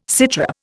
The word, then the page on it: sitrep: Indian